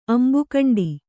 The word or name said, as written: ಅಂಬುಕಂಡಿ